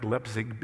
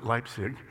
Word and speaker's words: Leipzig: It looks like Leipzig, but they pronounce it Leipzig.